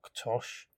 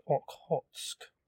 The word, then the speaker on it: Okhotsk: It's not pronounced Oktosh. It's pronounced Okhotsk, although personally, I think Oktosh is better.